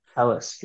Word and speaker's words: Telus: Then it changed to, is it Telus or Telus, how it's pronounced? – Telus, yeah.